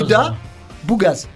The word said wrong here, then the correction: Ouga Bougaz: Ça se prononce pas comme ça, c'est moi qui l'ai mal écrit. – De lecture, je pense. – C'est moi qui l'ai mal écrit. – Ouais, tu l'as mal écrit. C'est Ouga Bougaz.